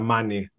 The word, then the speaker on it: Mani: So you guys are in, is it pronounced Manny or Mani, or how's it pronounced in Greece? – You explain it. – The little peninsula on the Peloponnese, the middle peninsula there is Mani.